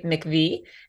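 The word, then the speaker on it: MIECHV: So it is pronounced very officially McV, and it stands for the Maternal, Infant, and Early Childhood Home Visiting Program.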